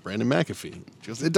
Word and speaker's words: McAfee: We're both McAfee. It's the only way you really pronounce the name.